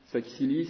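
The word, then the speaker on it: Saquisilí: Sakisili, ça s'écrit S-A-Q-U-I-S-I-L-I.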